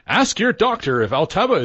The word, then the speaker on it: Altaba: It'll be how do you pronounce it? – Altaba.